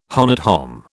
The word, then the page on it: honnête homme: British English